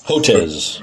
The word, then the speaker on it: Hotez: Of course, I learned that HOTUS, Is that how you pronounce it? Hotez? Hotez? – Hotez.